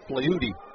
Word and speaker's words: Fleury: I mention that because Jose Flaherty, the last name is F-L-E-U-R-Y, but it is pronounced Flaherty, as if with a D.